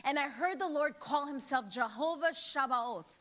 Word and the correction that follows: Sabaoth: uh, Sabaoth let me say it like that in Jewish that means he is the Lord of hosts.